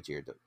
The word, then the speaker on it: Girardot: we're going to go Girardot.